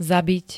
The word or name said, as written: zabit